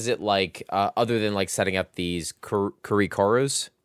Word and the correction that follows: Karakuri: Karakuri. Sorry. Please. Can you fucking nail it down for a second? – You know what? Say it one more time. Can you say it phonetically so I can write it down? I have a note card. – That's K-A-R-A. – Okay. – Curry, K-U-R-I. – Kara Curry. Got it.